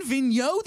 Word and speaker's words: Vigneault: Like a French-Canadian named Elaine Vigneault. There's a silent G in there.